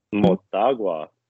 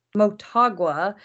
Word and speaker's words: Motagua: How do you say it again? Mota. – Motagua. – Motagua because they they gave Seattle a, a, little run for their money whenever they played them um, and Pachuca.